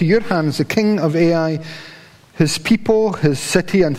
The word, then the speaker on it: Ai: AI is how it's pronounced.